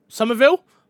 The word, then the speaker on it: Somerville: We got Somerville. Have I said that right? I feel like I said that wrong. – Somerville? – Thank you. Sorry, I pronounced that wrong.